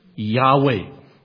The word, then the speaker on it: Yahweh: Now, there are two main suggestions as to how that name is pronounced. One, I already slipped in there. Yahweh. That is the majority position, well established. But there is a minority position that says that the sacred name was pronounced Yahovah.